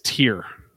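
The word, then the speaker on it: Týr: And then Tire. Black Tear. Sabbath. Sorry. – Yeah. Please. We just did the Norse pronunciation on YouTube. It's Tear.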